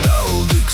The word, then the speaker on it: Талдык: Это Талдык, слышишь? Ударение на первый слог.